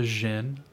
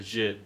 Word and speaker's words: djinn: how do I pronounce this? Gene? Like a genie? It's a guide to the Arab spirit world. – A djinn? – A djinn, yes.